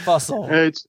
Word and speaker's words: Fussel: Fussel. – Just remember, it's like Russell with an F.